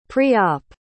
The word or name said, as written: pre-op